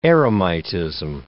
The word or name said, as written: eremitism